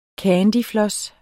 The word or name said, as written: candyfloss